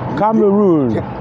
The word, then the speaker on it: Cameroon: Cameroon.